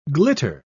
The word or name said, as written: glitter